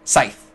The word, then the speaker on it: Scythe: That's not how you say it. – Scythe. S-C-Y-T-H-E.